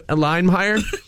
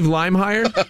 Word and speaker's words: Leimeir: Steve Limehire. – Steve who? – Steve Limehire. – How do you spell that? – L-E-I-M-E-I-R.